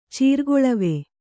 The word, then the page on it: ಚೀರ್ಗೊಳವೆ: cīrgoḷave